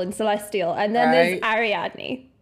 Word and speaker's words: Ariadne: Learns one of them is an enslaved dragon named Adarin? – Ariadne. – What? – Ariadne. I did actually look up how to pronounce this because I could not bloody read it. Ariadne.